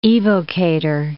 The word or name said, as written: evocator